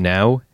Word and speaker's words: Nao: But even look at the first day, you've got, I always forget how to pronounce this lady's name, Now, N-A-O.